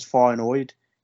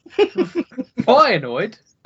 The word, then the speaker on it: Feyenoord: Feyenoord? – How would you pronounce it? – Feyenoord, not Feyenoord. I'm Feyenoord with your pronunciation there.